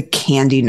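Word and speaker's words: KNDy: but commonly called the candy neurons. It's pronounced like the chocolate stuff, but it's spelled with a K. K-N-D-Y.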